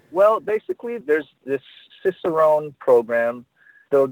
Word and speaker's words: Cicerone: And that's kind of a good segue to what Cicerone, is that, am I pronouncing that right? Cicerone? – Yeah, that's right, Cicerone.